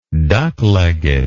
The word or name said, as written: duck legged